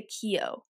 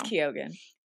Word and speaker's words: Keoghan: I think, well, I've heard it say like people say Keo because he's from like Ireland or something. So it's like pronounced, I don't know. – It's Keoghan.